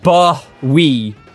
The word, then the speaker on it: Bowie: His stage name is pronounced Bowie, to rhyme with Doey or Joey. – Bowie! I will go see David Bowie!